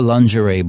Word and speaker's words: lingerie: Un altro spoof è quello della lingerie ball. – Scusa, si dice lingerie. – Lingerie, lin, perché in francese si pronuncia così, lin.